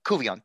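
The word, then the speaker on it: Couvillon: That does seem to be a common theme amongst some of the veteran political analysts like John Colvin of Louisiana. – Cuvion, thank you. I still get those names wrong.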